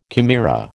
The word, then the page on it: chimera: American English